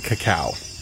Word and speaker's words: cacao: I think the pronunciation might be cacao. – Cacao.